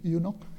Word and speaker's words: eunuch: So on the way, Philip met this Ethiopian Enoch. How do you pronounce it? Eunuch.